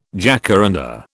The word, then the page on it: jacaranda: British English